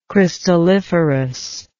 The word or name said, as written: crystalliferous